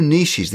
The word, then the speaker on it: niche: Those you might call your niches or niches, as you say in the States. – Yeah. – Incorrectly, by the way. It's a French word.